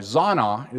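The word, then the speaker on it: zonah: Zana is the way it's pronounced.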